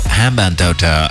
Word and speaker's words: Hambantota: Gail Road, Hamantota. – G- Gale, Hambantota, Hambantota. No, I was like, there has to be a way of changing that.